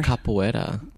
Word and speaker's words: capoeira: capoeira.